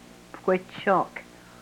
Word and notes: pkwechok: Yurok

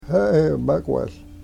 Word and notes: haagomekwehl: Yurok

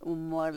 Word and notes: u-mol: yurok

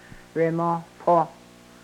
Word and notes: remohpoh: Yurok

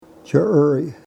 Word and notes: chyrry: Yurok

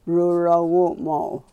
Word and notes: rurowuumow: yurok

